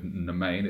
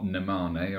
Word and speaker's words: Nemane: He's quite happy with Nemain. It's not Nemane or anything like that.